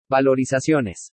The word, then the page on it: VALORIZACIONES: spanish